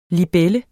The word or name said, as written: LIBELLERNES